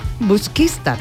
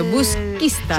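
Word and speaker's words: Busquístar: Ahora vamos a retomar la comunicación para hablar de este festival de jazz de Busquistar. ¿Lo he dicho bien ahora? – Busquistar. – Busquistar. El acento. Me ha faltado el acento. Busquistar.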